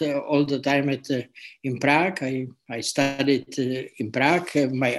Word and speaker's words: Prague: In Prague is pronounced, not Prague.